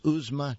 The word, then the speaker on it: Uzma: Again, this book is called Hannah Khan Carries On, and that's spelled H-A-N-A-K-H-A-N, Hannah Khan. And the author is Uzma Jalaluddin, Uzma U-Z-M-A, and Jalaluddin is J-A-L-A-L-U-D-D-I-N, Jalaluddin.